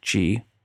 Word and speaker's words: Qi: The iPhone 10 has the same wireless charging as the 8 and the 8 Plus, which works with Qi. Qi is spelled QI, charging tech.